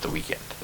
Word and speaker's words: The Weeknd: His name is The Weeknd. Pronounced The Weeknd.